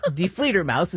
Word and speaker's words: Die Fledermaus: but no, it's not. It's Die Fledermaus is actually how you say it.